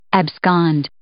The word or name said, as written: abscond